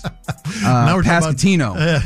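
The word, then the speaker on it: Pasquantino: They'll play again tonight as well against the Royals, who will not have Victor Pascarini. Wait, I said that wrong. Goodness gracious. Pascatino.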